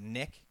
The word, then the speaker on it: Nick: my name is Nick. – Mm-hmm. – But so many times people are like Mick with an M.